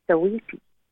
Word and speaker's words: Saweetie: a hip-hop artist called Saweetie. I think that's how it's pronounced. – That's right, yeah.